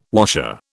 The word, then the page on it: washer: British English